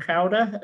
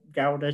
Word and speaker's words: Gouda: I went to um, visit a mate of mine who lives in Cowder earlier, or Gouda to use the English pronunciation, home of the cheese.